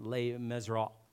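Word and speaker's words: Les Misérables: Now, the whole pronunciation, Les Miserables, says that LS at the end of it, but you don't say that.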